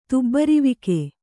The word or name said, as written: ತುಬ್ಬರಿವಿಕೆ